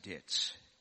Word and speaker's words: debt: Then Nita, you spoke on "Forgive us our debts as we also have forgiven our debtors." I had to tell her when we practiced this beforehand that you don't pronounce the B in debt. You just ignore that.